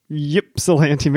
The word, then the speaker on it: Ypsilanti: What I pronounced as Ypsilanti, Michigan. – Ypsilanti. – I was wrong. – We put out a call for somebody to please correct us. We do care about the words we use. So I got an email this week. It said, "Hey guys, thanks so much. We listened to your last episode specifically about the brewer from Ypsilanti, Michigan, pronounced Ypsilanti. It's no bother, though. Everyone pronounces it incorrectly, even Michiganders.